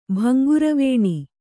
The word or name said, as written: ಭಂಗುರವೇಣಿ